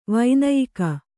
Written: ವೈನಾಯಕ

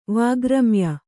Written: ವಾಗ್ರಮ್ಯ

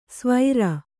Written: ಸ್ವೈರ

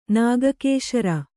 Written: ನಾಗಕೇಶರ

